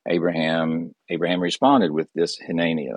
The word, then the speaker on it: hineni: Uh, it's, it's the word hineni. Um, if you were to write it out, it'd be H I N E N I, pronounced Hineni.